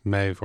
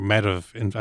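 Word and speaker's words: Medb: So are there more representations of Maeve or Medivh, as the old Irish pronunciation would have been Medivh, that's been modernized to just Maeve, straight Maeve? – Exactly, yeah.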